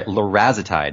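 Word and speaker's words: larazotide: And what I did find really cool about this, and I didn't know, is that there's a drug called lorazide. Have you heard of this? – No, sorry. – Lorazitide. That's the right pronunciation.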